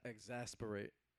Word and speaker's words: exasperate: It's exasperate. – Exasperate, yes. Amen.